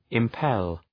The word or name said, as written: impel